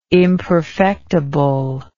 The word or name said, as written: imperfectible